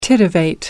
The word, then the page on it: titivate: American English